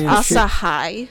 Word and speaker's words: Asahi: Some it's pronounced Asahi.